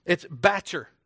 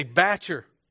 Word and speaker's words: bacher: It's bacher. You say bacher.